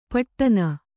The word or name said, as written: ಪೀಡನ